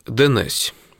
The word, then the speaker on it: днесь: То есть, например, вот слово «днесь» в некоторых певческих произведениях произносится как «денесь».